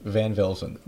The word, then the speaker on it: Van Velsen: It's really phonetic. Van Velsen.